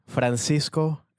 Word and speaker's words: Francisco: How would you pronounce your name? – You want me to pronounce it like Spanish accent? – Traditionally, like? Yeah. – Francisco Ramos.